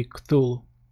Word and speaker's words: Cthulhu: Or Cthulhu, cum îi spun unii. E greu de pronunțat, dar în principiu e Cthulhu. Chemarea lui Cthulhu.